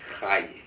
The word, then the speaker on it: Rayi: But I just want to also, my surname is R-A-Y-I. It's pronounced Hai.